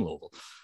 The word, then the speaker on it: Louisville: I'm in Louisville, luckily, I think, you know, but I'm not from Louisville.